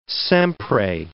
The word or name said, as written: sempre